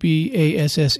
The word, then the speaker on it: Bass: And folks, Bass is spelled Bass. – Yes, like the fish. – And because some people put Basse, so I just want to make sure.